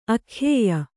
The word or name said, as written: ಅಖ್ಯೇಯ